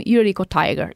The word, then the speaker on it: Yuriko Tiger: And everywhere, like TikTok, and Twitter, Instagram, YouTube, with the name Yuriko Tiger. It's the same for every social. – Yuriko Tiger. – Tiger. – Yuriko Tiger. Just spell it like it's written.